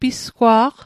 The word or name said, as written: pisgwa'q